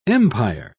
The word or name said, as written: empire